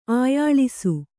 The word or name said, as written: ಆಯಾಳಿಸು